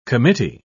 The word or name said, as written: committee